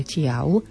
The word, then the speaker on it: tiav: To isté máme potom aj v tej poslednej slohe, kde hovoríme o záplave tiav. Pozor, tu je na konci slova V a vyslovujeme ho ako U.